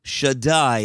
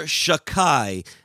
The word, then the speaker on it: Shaddai: So too with the Shin Dalet Yud. It would be formally pronounced Shaddai in educational spaces or in tefillah, in prayer. And in more informal spaces, you'll hear Shakai, as to break it up a little bit and not actively use one of the names of God in vain, God forbid.